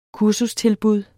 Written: kursustilbud

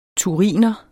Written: thuriner

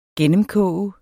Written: gennemkoge

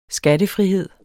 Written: skattefrihed

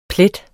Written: plet

